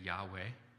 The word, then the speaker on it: Yahweh: The intimate name of the Lord, it's spelled YHWH, no syllables in Hebrew. However, when we have to pronounce it in English, we say Yahweh.